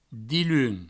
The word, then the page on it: diluns: dilun; Provençal